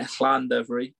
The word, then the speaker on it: Llandovery: Yeah, Llandovery, but yeah, you're all right.